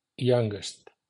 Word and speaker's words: youngest: Los adjetivos terminados en el sonido ng, ng, independientemente de cuántas sílabas forman el adjetivo, cambian al sonido ngest, tomando un sonido g más. Así, young, youngest, strong, strongest, long, longest, pero wrong no toma otra G en la pronunciación del superlativo.